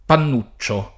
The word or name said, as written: Pannuccio